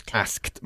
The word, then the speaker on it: asked: Porque ask no acaba en el sonido de una T. Mm-hmm. Okay. Good. Y por lo tanto, no pronunciamos la E final. – All right. – Asked.